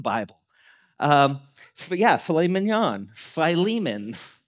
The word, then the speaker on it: Philemon: And we're going to go through a short book in the New Testament of filet mignon. I don't know if you've ever seen that. It's Philemon. That's how to say it.